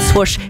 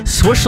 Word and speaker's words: swoosh: I say that you say it swoosh. He says you say swoosh. Swoosh like bush, not swoosh, no way, because swoosh, it rhymes with douche.